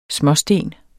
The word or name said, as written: småsten